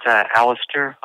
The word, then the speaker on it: Alistair: It's Alistair, like Alistair Crowley.